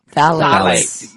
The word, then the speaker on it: phthalates: It's called ptyalates or ptyalates? – Phthalates. – Yeah, phthalates. Yeah.